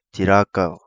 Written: Tirakau

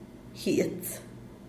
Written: giets

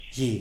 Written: gi

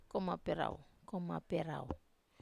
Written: komap rau

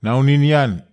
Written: naonin nian

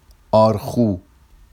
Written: ɑrxu

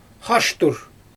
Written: xaʃtur

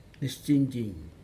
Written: nestin jin

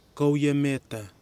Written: kawiameta

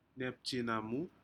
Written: nepjenumu

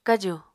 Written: kaju

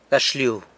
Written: gaxlyu

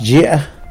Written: zhi

